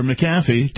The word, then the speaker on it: McAfee: At any rate, we've been talking about tech titan John McAfee, or McAfee, depending on your preferred pronunciation.